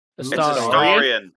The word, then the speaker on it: Astarion: Name wrong. – Astarion. – Astarion. – Oh, pardon me. I'm so sorry. – With the long A. Not a soft A, a long A.